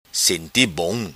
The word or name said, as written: Senti Bom